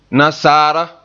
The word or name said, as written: nasaara